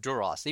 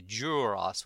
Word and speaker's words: Duras: Patrick would always say Duras with a Y in it, like D-U-Y, which is actually the way the pronunciation guide says to say it. – Oh, really? – Yeah. And everybody's anglified it to Duras.